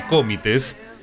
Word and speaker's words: Comites: Mira, le quiero dedicar el programa también a Darío Signorini, que es el actual presidente del Comites, así se pronuncia, me dijeron, Comites, de Buenos Aires.